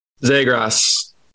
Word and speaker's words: Zegras: And if you put him on a team and you got Trevor Zegras, he can give him some time to grow. What? How do you say his name? – Zegras. – How the heck am I supposed to know that? Zegras.